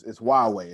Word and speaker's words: Huawei: It's Huawei. It doesn't look like that, but that's how you say it, okay?